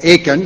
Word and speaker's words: Achan: But when we come to Joshua chapter 7, we find that one man, Achan, as we heard his name pronounced last week, or Achan if we anglicize it, one man didn't obey.